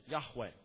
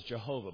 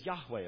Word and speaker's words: Yahweh: That's God's name in the Old Testament, Yahweh. Now, in some English languages, that's slaughtered as Jehovah, but that couldn't possibly have been the way it was pronounced in Hebrew originally.